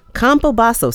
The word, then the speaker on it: Campobasso: Craig Combasso. Actually, I've been saying his name wrong. Combasso. C-A-M-P-O-B-A-S-S-O.